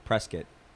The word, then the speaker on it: Prescott: Prescott. – Yeah. – Is that right? – Yeah. – Yeah. Anyways, is Prescott like a town that's like in the middle of nowhere?